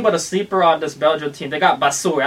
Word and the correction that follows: Batshuayi: I don't even know if I said his name right. – Batshuayi.